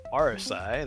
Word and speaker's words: Aresye: Yeah, I am Aresye. That is the correct pronunciation. Many people get it wrong.